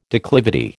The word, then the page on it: declivity: American English